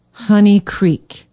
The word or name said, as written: honey creek